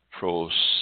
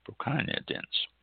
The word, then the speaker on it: procyanidins: That these wines' tannins not only scrape fat from the palate and digestive tract, but they are a rich in healthy heart-helping procyanidins. I pronounced that wrong. Procyanidins. It has to be cayenne because procyanidins.